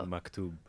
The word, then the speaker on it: Maktub: Maktub.